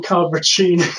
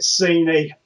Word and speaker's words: Cappuccini: Wayne, thank you for correctly pronouncing Cappuccini. I was on another show earlier, and pretty much everyone always pronounces it Cini. But as I discussed with him, it's like I thought about changing it to be spelled CH, but I think people would then possibly pronounce it Chinny, yeah.